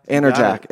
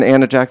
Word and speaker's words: Anajak: Anajak. – It. – But there's an R in there. – Nope. – There's no R in Anajak Thai.